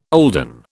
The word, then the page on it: olden: British English